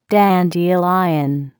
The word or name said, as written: dandelion